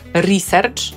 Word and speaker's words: research: A dlaczego po polsku mówimy research, akcentujemy pierwszą sylabę? Bo to brzmi prawie tak samo jak wymowa amerykańska.